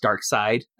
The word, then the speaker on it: Darkseid: who I believe is pronounced Darkseid. I remember long debates as to whether this was right. Darkseid or Darkseid, because it's spelled Darkseid.